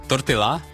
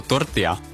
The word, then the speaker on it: tortilla: Češi říkají, tortilla, je to tortilla. – Jo? Tortilla. Ano, takže poupravujeme výslovnost tortilla.